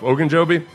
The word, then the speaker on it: Ogunjobi: Ogunjobi? Is that how you say it? – Yeah, I think so.